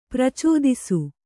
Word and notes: ಪ್ರಾಚೇತಸ: prācētasa

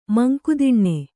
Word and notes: ಮಂಕುದಿಣ್ಣೆ: manku diṇṇe

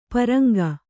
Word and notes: ಪರಂಗ: paraŋga

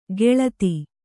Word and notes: ಗೆಳತಿ: geḷati

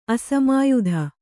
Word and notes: ಅಸಮಾಯುಧ: Kannada

